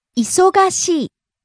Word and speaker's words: 忙しい: 忙しい。 – Busy. – 忙しい。忙しい。 – Rising on the second syllable, so, and coming down on the final syllable, い。